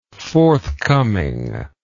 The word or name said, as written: forthcomingness